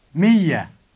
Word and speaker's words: مية: If you want to say 100 riyals, you should add a T sound at the end of the word miyya.